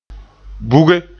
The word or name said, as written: buge